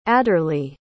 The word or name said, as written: adorlee